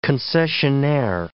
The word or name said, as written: concessionaire